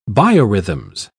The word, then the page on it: biorhythms: British English